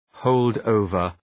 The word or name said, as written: hold over